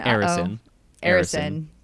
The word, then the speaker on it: Arison: But also there was the son of the Heat the Heat's billionaire owner, Nick and Arison. – Thank you. I warned about my pronunciation thing before the pod. So Nick Arison was there.